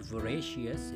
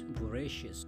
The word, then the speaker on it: voracious: It's not voracious, it's voracious. So, it's easy to uh, say it like voracious or just like delicious or something like that,